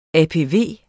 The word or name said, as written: APV